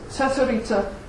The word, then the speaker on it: Cesarica: C is pronounced like a TS. Cesarica and Jablanac, about 20 miles apart.